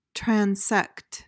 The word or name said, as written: transect